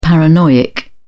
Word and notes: paranoiac: British English

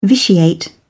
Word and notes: vitiate: British English

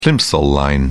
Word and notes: plimsoll line: British English

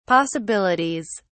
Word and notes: possibilities: British English

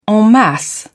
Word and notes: en masse: British English

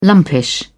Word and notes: lumpish: British English

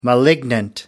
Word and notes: malignant: American English

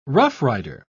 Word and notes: roughrider: British English

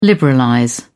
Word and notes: liberalize: British English

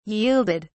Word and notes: yielded: English; British English

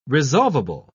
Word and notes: resolvable: British English

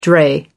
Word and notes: dray: American English

